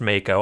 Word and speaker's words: Mako: it is draining this force Mako, which is absolutely supposed to be pronounced Mako. I don't care what anybody says.